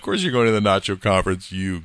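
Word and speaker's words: NACCHO: I went with my friends from Public Health down to their annual, now they pronounce this NACHO conference, the National Association of City and County Health Officials.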